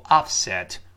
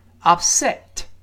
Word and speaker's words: upset: 那么 upset 注意重音一定在第二个音节， the accent is on the second syllable，不要读 upset。我们跟老师读一下这个词 upset， upset。